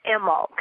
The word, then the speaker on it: Imbolc: So, Imbolc is, it's actually an old, it's pronounced Imulk. It's spelled Imbolc.